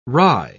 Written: wry

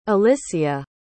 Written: alysia

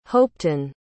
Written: hopeton

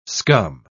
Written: scum

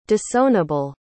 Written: disownable

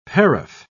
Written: paraph

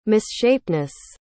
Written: misshapenness

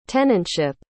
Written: tenantship

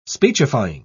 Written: speechifying

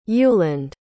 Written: uland

